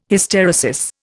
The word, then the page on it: Hysteresis: Indian